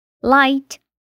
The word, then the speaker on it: light: Também pode ser que fique silenciosa na combinação GH se vier depois de som de vogal, como em light, leve, though, porém, daughter, filha, e high, alta.